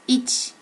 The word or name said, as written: ichi